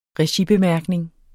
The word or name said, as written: regibemærkning